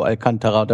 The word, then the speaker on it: Alcantara: Von meinem Thiago Alcantara, da wird so ausgesprochen. – Deswegen nehmen wir das jetzt auch als richtig an.